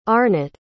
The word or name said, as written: Arnott